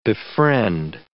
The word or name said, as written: befriend